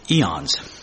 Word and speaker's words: aeons: That's spelled A-E-O-N-S. And pronounce it eons.